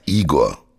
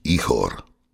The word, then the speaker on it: Ihor: Dieser Krieg macht keinen Sinn, sagt Igor, ukrainisch ausgesprochen eigentlich Ihor.